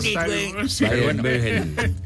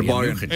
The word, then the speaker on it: Bayern München: ¿Qué es el Bayern Múnich? ¿No? ¿Cómo le dices tú? – Bayern. Al rato lo vas a ver llegar con su. – La pronunciación es de donde es origen. – Claro, el Bayern München.